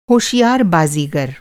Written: बाज़ीगर

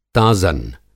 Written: ताज़न

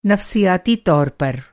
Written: नफ़्सियाती

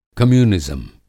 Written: कम्यूनिज़्म

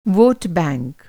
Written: वोट बैंक

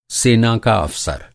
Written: अफ़सर

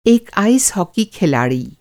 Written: आइस हॉकी

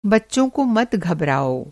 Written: घबड़ाना

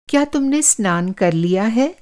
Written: स्नान

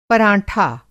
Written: पराँठा

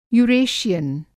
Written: यूरेशियन

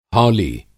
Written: हौली

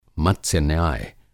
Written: मत्स्य-न्याय

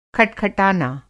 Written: खटखटाना

